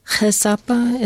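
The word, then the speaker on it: He Sapa: Chesapa, it's spelled H-E, it's two words, H-E, and Sapa, S-A-P-A.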